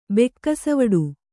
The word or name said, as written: ಬೆಕ್ಕಸವಡು